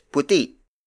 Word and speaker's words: puti: The opposite of itim is puti. Puti puti. Puti is spelled as P U T I.